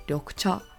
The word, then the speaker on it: 緑茶: And in Japanese, we call this 緑茶.